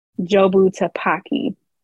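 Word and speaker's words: Jobu Tupaki: Also, I've been saying her name wrong. It is Jobu Tapaki. Not Jobu Jabaku or whatever, but whatever I was saying.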